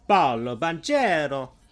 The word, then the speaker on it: Paolo Banchero: Paolo Banchero. There we go. That's how you say it. I keep thinking of him like as if he was Brazilian, but no, he's Italian American.